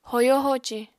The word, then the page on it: hoyo-hoci: apatani